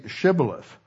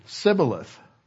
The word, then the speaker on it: Shibboleth: And if they would say, say Shibboleth, and he would say Sibboleth, for he could not pronounce it right.